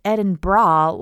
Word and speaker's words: Edinburgh: So if you ever get a chance to go, definitely go to Edinburgh, spelled Edinburgh, pronounced Edinburgh, like the thing half the population has to take off as soon as we get back home from work. And I'll put some pictures up on Twitter and on Instagram.